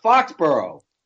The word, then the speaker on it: Foxborough: No, it's Fox, B-O-R-O-U-G-H. Is how you spell it. Yeah, but it's still pronounced Foxborough. – Foxborough, correct. You realize this isn't the BCS, right?